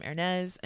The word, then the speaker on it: Ernez: Of course, just put in my first name, Ernez, and it's spelled HERNEASE.